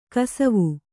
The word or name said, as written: ಗೊಸಾವಿ